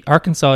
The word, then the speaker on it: Arkansas: Arkansas is. – I thought that was an Indian thing. – It's a Frenchification of an Indian word. – Gotcha. – And that's why there's a silent S.